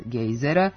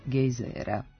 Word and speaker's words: gejzera: U promeni pravilni su oblici sa kratkouzlaznim akcentom na prvom slogu gejzira, to jest gejzera, i sa dugouzlaznim na drugom slogu gejzira, to jest gejzera.